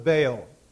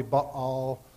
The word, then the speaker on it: Baal: Baal. For those of you who are technical, the pronunciation is probably Baal, but most people say Baal.